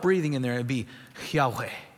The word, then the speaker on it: Yahweh: Again, in North Carolina, we would pronounce it Yahweh. The Hebrews would approach it differently. There would be more of that rough breathing in there.